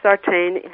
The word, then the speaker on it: Sartain: Sartain, yes.